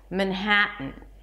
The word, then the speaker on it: Manhattan: The second syllable is ha, an H sound, and you have to pronounce it, so it's not Manhattan, but mnha, and drop your jaw for the a as in cat, Manhattan. And then, that's the trick, we have a T, schwa, N.